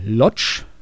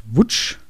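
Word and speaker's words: Łódź: Juli 1948 in Lodz, spricht man es, glaube ich, deutsch aus. Im Polnischen wird es, glaube ich, Wutsch ausgesprochen.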